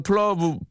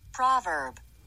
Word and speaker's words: proverb: 아, 제 발음으로는 플러브, 플러브인데 이게 제대로 맞나 봐봐요. – proverb.